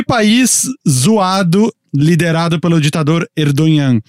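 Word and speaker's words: Erdoğan: Erdogan. – Erdogan.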